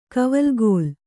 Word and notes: ಕವಲುಗೋಲು: kannada; kavalugōlu

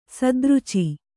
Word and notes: ಸದ್ರುಚಿ: sadruci

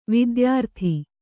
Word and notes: ವಿದ್ಯಾರತೆ: vidyārate

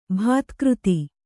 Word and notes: ಭೌತಿಕತೆ: bhautaikate

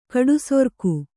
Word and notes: ಗಡಿಸರಕು: gaḍisaraku

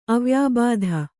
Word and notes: ಅವ್ಯಾಬಾಧ: avyābādha

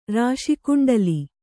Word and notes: ರಾಶಿಕುಂಡಲಿ: rāśi kuṇḍali

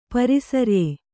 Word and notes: ಪರಿಸರ್ಯೆ: pari sarye

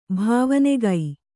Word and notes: ಭವನಿಕೆ: kannada

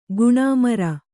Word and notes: ಗೋಣುಮುರಿ: gōṇumuri; Kannada

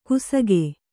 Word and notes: ಕುಸಗೆ: kannada

